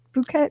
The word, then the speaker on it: Phuket: Is it pronounced Phuket, Thailand? Phuket? – Yeah.